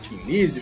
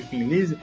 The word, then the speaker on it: Thin Lizzy: Tim Lizzy, Fin Lizzy. As duas estão corretas. É porque quem fala Tim Lizzy é em cima do nome que gerou o, essa grafia, que é o Tim sem H e IE no final. Só pra. Acho que é vale falar que sempre tem um chato que vai falar de pronúncia. Sempre vai ter um professor de inglês atrás do computador enchendo o saco. Então tá, os dois estão certos, só para constar.